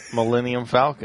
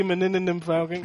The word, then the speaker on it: Millennium Falcon: Millennium Falcon. – Damn it. – However. – He has practiced. It used to be Meninendum Falcon.